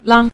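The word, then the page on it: lang: tone 5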